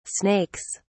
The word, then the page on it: snakes: British English